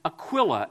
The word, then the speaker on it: Aquila: And so I went back and I did more research, and I found that there's an alternative way to pronounce it, and that is Aquila.